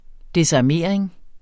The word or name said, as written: desarmering